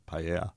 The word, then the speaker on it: paella: I've been corrected in Dublin in a restaurant for pronouncing it wrong. – By who? – The guy who owns Havana on George Street. – Right. – He was like, it's paella.